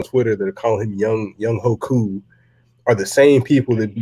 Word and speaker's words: Younghoe Koo: And we signed a part ways with Falcons legend Matt Bryant, and we have signed Younghoe Koo. – Thank you for saying his name right.